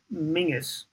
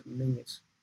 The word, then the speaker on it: Menzies: Well, there was a guy called Stuart Mingus, you know, which is spelled Menzies, but they pronounce it Mingus.